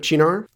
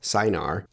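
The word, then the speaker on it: Cynar: So in summary, what do I think of Chinar? I'm glad I learned to pronounce it for one. I always said Sinar. It looks like it should be Sinar in English, but indeed it's Chinar.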